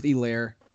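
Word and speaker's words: Helaire: My sit-em is Edwards, Elaire. It's Elaire. I think the H is silent.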